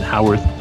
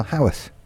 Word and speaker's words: Haworth: He's in Haworth, UK. Did I pronounce that right, by the way? I mean, Americans should say Haworth. – No, you did very well. Haworth.